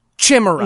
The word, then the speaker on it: chimera: from folks who wanted to let me know that I mispronounced the word chimera. Again, I pronounced it chimera. So again, that word is pronounced chimera.